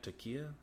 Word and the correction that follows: Taika: Is that how you say that? No, Taika. Taika. Yeah, I said it right the first time.